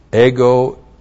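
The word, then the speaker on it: ego (ἐγώ): E-G long O. And ego is us.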